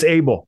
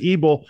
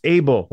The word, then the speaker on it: Ebel: It's Abel. E-B-E-L. Most people would pronounce it Abel, but it's Abel, like Cain and Abel.